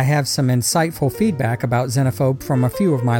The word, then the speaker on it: Xenophobe: Well, I know that in America it's generally pronounced Xenophobe, with the long Z. But in the rest of the English-speaking world, and most notably within the UK and its former colonies, such as Australia, India, and Canada, it's generally pronounced Xenophobe, with a short Z, or Z. Now, I'm an American through and through, but I actually prefer the British pronunciation, Xenophobe, just because Z sounds so horribly horizontal to my musically trained ear. – Xenophobe is the correct pronunciation, so bully for you.